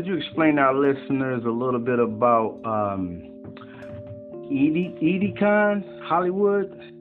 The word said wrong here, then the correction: Ideagen: I don't know if I'm saying that right. Edecon Hollywood? – Oh, you mean Ideagen? – Oh, okay, yeah.